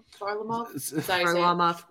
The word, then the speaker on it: Varlamov: So the second guy he listed, what was it, Varlamov? – Varlamov.